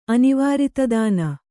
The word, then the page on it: ಅನಿವಾರಿತದಾನ: kannada